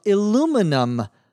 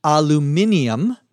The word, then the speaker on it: aluminium: She says that she suggested some aluminum light fixtures for the living room, and he, her client, told her he wanted solid silver. Aluminum, pronounced in British English, aluminium, because it's spelled different, is a relatively inexpensive or cheap metal.